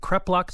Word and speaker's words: kreplach: This photo of kreplach, is it kreplach, is that how you pronounce it? – That's correct.